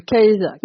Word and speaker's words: Kaïsa: Pour cette dimension-là, justement, alors Virginie, donc on l'a évoqué là avec le royaume, enfin Kaïsa, tant que je prononce ça bien, que je ne me trompe pas, le Kaïsa, c'est comme ça que tu dirais ? – Oui, c'est comme ça que je le dirais.